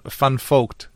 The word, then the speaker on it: Van Vogt: war Alfred Elton van Vogt. Aber er war kein Holländer. Er war Kanadier und Amerikaner auch noch obendrein. Mhm. Und Van Vogt spricht man es aus in den USA.